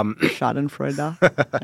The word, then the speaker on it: Schadenfreude: There's an element of like Schadenfreude. – Mm-hmm. – Did I pronounce that right? – Yeah. Schadenfreude, I believe it is.